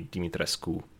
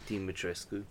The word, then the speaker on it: Dimitrescu: The correct pronunciation would be the closest to how it just reads, which would be Dimitrescu. You know, you actually say the U at the end. – Dimitrescu.